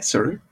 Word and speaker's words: Saru: I always want to say Saru. Saru. I think that's, I'm pronouncing it right. I'm sure someone's going to correct me. Help me off and correct me. – I think so, yeah, Saru.